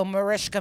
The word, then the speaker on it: Mariska: Mariska Hargitay. – Mariska. – I thought it was Marissa Hargitay. – Mariska. Really? Hars. – Mariska. I like to do a joke where I go, Mariska makes us hard and gay.